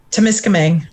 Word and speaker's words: Timiskaming: Timiskaming.